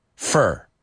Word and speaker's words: for: When we use the word for, para, F-O-R, we pronounce it F-E-R. What do you need that for? Fur.